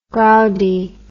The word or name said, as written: groudy